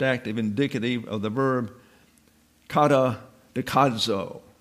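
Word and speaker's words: kata dekadzo (καταδικάζω): There's that Z again that requires a D in pronunciation. You can't say dekadzo. You have to put a D in front of it if you want to pronounce it properly.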